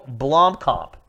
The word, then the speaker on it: Blomkamp: This is a special trailer, a live-action trailer, directed by Neil Blomkamp. Is that right? – Blomkamp. – Blomkamp. I was close.